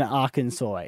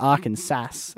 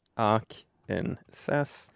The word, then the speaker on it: Arkansas: I know it's not Arkansas as it is spelt. – Arkansas.